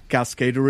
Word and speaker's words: CASCDR: Because your company is spelled CASCDR. – That's right. – Yeah. Cascader it is.